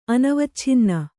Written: ಅನವಚ್ಛಿನ್ನ

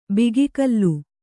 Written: ಬಗೆಗಲ್

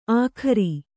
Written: ಆಖರಿ

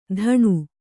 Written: ತಣು

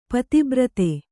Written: ಪತಿಬ್ರತೆ

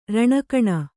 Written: ರಣಕಣ

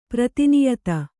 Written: ಪ್ರಾಧಾನ್ಯತೆ

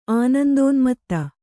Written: ಆನಂದೋನ್ಮತ್ತೆ

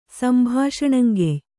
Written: ಸಂಭಾಷಣಂಗೆಯ್

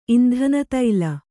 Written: ಇಂಧನತೈಲ

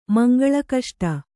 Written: ಮಂಗಳಕಷ್ಟ

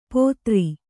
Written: ಪೋತ್ರಿ

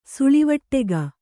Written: ಸುಳಿವಟ್ಟೆಗ

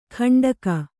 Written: ಖಂಡಕ

